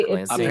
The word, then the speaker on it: Ypsilanti: I'm in Ypsilanti. – Ypsilanti. – If I'm pronouncing it right. Locals say Ypsilanti.